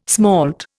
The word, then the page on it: smalt: Indian